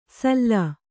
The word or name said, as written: ಸಲ್ಲಾ